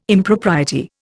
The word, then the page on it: Impropriety: Indian